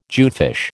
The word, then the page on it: jewfish: American English